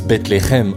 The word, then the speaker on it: Bethléem: D'ailleurs, petite pépite, Bethléem, qui se prononce Bethléhem en hébreu, se traduit littéralement par maison du pain.